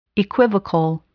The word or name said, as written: equivocal